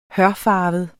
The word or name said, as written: hørfarvet